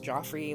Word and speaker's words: Joffrey's: So she is driving, and decides to pull into this fancy beachside restaurant called Joffrey's, spelled like Jeffrey with a G, but it's pronounced Joffrey like the Game of Thrones stupid king guy.